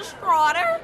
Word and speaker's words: Stroughter: My name is Dinesha Schroeder. First name is spelled D-O-N-A-Y-S-H-A. Last name is spelled S-T-R-O-U-G-H-T-E-R.